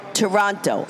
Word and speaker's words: Taranto: Because when we did that Facebook Live, if anybody's watching, her name is spelled T-A-R-A-N-T-O. You would think it's Taranto, but she said, no, it's Toronto. I said, oh, that's like saying John is spelled S-U-E.